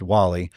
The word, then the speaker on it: Guale: It's actually G-U-A-L-E, but it's pronounced Wali.